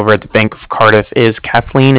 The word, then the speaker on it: Cardiff: And I was calling with the Bank of Cardiff, and Cardiff is spelled C-A-R-D-I-F-F, as in Frank Frank, so it's like car and then different.